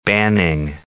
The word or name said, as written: Banning